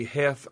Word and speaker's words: Heth: Kirby Smith had approximately 10,000, but Henry Heth, or Heath, I know it's pronounced both ways, his division stayed at Barbeville.